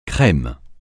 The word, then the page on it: crème: French